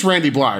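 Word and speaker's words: Randy Blythe: It, like, there's a really cool contrast going on here between, it is Randy Bly, right? The Lamb of God guy. – Yes.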